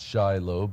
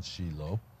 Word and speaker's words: Shiloh: And I've been living in Israel for 32 years, and I moved to Shiloh, or Americans would call it Shiloh, but we use the proper Hebrew pronunciation, Shiloh.